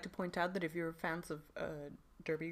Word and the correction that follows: Derry: Girls. – No, not Derby. – How do you say Derry. – Derry! Gosh, Derry Girls.